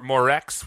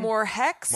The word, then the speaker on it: Morgex: Morex, we're saying? – Morhex? Morgex? – Why do we think it's pronounced H? I don't know how Italians have G's, right? – Yeah, but this is not like...